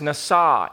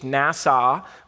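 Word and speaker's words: nasa (נסה): The Hebrew word is nasa, N A S A H, nasa, or nasa, if you emphasize the second syllable.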